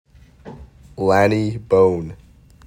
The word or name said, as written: L.J. Bohne